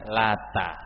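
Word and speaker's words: lata (اللات): Nah, batu ini bacanya lata. Kalau ditasydid nama orang, kalau takhfif tidak tasydid itu nama batu tempat buat roti disembah.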